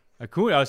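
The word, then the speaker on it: Acuña: Is it Acuna? Am I pronouncing that? You can see how into baseball I am. – Acuna.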